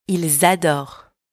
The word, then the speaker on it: ils adorent: Ils adorent. Ils adorent. Last one. Elles adorent. Elles adorent. And don't pronounce the ENT at the end, okay? And if you want to know why so many silent letters in French, then check out my course, French Pronunciation Made Easy.